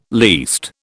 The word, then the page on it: least: British English